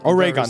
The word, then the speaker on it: Oregon: Oregon, thank you.